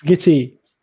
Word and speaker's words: Gitea: So what I'm using currently is called Gitea, I guess that's how it's called. – It's pronounced Gitea. – Yeah, Gitea. Yeah, it's because it's, you know, and yeah, it's a good service.